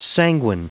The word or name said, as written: sanguine